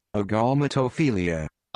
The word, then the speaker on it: Agalmatophilia: Agalmatophilia. – Agalmatophilia. A-G-A-L.